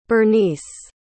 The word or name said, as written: Berniece